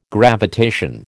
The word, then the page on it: gravitation: American English